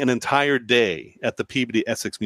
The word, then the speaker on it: Peabody: There is a town next to Salem that is spelled P-E-A-B-O-D-Y, and those words are P and body. And when you put them together, it's Peabody. I didn't make the rules. That's how it's pronounced.